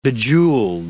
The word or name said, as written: bejeweled